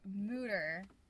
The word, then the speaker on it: Mütter: And his friend's son, who was about 10 or 11, to the Mütter Museum. – Correct. Good pronunciation. – But I still want to call it Mütter.